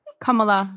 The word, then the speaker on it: Kamala: We can thank Miss Kamala Harris. – Kamala, sorry.